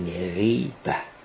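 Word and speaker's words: غیبة: تلفظ درستش به عربی به کسر غین غیبه.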